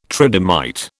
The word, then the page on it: tridymite: British English